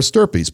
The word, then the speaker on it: stirpes: It's as simple as putting comma per stirpes, P-E-R-S, and the new word S-T-I-R-P-E-S. Stirpes rhymes with something else. – Burpees? – Burpees.